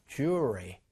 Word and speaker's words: jewelry: 자, 자연스럽게 영어로도 jewelry. 요거는 지금 입천장에 혀가 닿아야 되고 요거는 닿으면 안 되는 거거든요. 그렇기 때문에 자연스럽게 어떻게 돼요? 요 L 사운드가 거의 없어져요. jewelry. jewelry. 이렇게 발음이 됩니다.